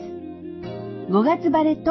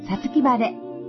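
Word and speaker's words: 五月晴れ: 5 月の晴れと書いて読み方が 2 つあります。 5 月晴れとさつき晴れ。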